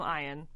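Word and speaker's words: Eoin: I think it's there's so Ian. Many vowels. It's E-O-I-N. – I think it's Ian. – Okay, we're going to call him Ian.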